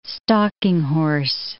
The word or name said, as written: stalking horse